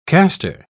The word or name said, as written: caster